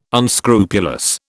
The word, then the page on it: unscrupulous: British English